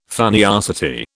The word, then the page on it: funniosity: British English